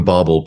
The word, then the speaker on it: Babil: And you participated in over 200 combat patrols in extremely violent Northern Babil province. Am I pronouncing that correctly? – Yes, the Northern Babil province of Iraq.